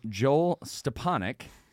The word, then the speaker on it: Joel Stepanek: And we're joined again on our bonus edition episode by none other than properly pronounced name Joel Stepanek.